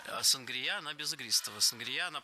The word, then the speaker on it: сангрия: На маяке у нас сангрия. Я сама слышала чаще вариант сангрия, а филологи рекомендуют говорить сангрия с ударением на втором слоге.